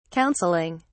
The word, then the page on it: counseling: British English